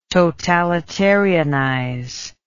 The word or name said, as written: totalitarianize